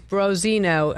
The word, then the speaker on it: branzino: He said, you said it wrong. It's Branzino. Branzino is what you ordered at La Scolio.